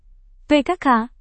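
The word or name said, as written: pkl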